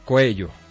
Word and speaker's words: Coello: Que lleven carros de bomberos, y escuché al alcalde de Coello. Sí lo dije bien, ¿cierto? – Sí, señor. – Coello.